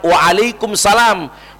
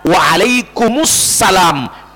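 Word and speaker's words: waalaikumussalam (وعليكم السلام): jadi bunyinya adalah waalaikumussalam, bukan waalaikumsalam.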